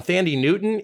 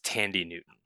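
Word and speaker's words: Thandie Newton: And the second one, Thandi Newton, is from none other than Kelsey B from Denver, Colorado. – And just to give you a little actually, I believe actually. It's pronounced Thandi Newton.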